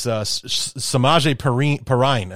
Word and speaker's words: Samaje Perine: But it was Samaje Perine, is how you pronounce his name, three touchdown catches in this one.